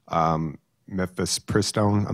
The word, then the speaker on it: mifepristone: And so there's also Walgreens and, you know, it's, that have stopped providing mifepristone.